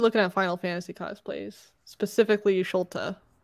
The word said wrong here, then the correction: Y'shtola: Oh, Y'shtola.